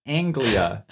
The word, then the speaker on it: Anglia: And so they drive the Ford Anglica. That is so hard to say. The Ford Anglica. Am I saying it wrong? – No, it's Anglia. There's no C in it. Ford Anglia.